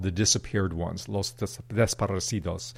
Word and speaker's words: desaparecidos: desaparecidos.